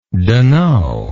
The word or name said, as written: dunno